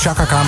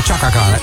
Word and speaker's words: Chaka Khan: Dat je niet zegt Chaka Khan, maar Chaka Khan.